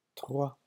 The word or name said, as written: trois